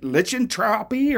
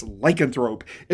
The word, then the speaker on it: lycanthrope: And so I remember this old lady, she like raises her hand, says, she tries to read it, she can't pronounce lycanthrope. She's like, lichentropy or whatever. And I was like, I raised my hands like, no, it's lycanthrope.